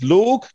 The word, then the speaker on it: Logue: I'm going to go with Logue. Is that a pronunciation? Are you happy with that? – Yeah, I like that. – L-O-G-U-E. I think it's because it sounds a little bit extra aggressive in my accent. Logue. – Logue. Yeah.